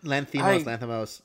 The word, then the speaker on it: Lanthimos: I really hope that, I'm sorry if I say his name wrong, Yorgos Lanthimos. Is that how you say that? – Yeah, Lanthimos. Yeah.